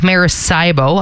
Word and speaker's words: Maracaibo: It's called Lake Maracaibo.